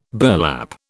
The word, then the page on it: burlap: British English